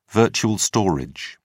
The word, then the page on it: virtual storage: British English